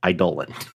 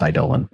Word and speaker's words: Eidolon: It is pronounced Eidolon. – Yep. Got to look at the root word. And it's definitely supposed to be pronounced Eidolon.